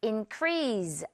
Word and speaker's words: increase: increase 아니고 increase. S 발음.